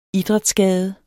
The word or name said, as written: idrætsskade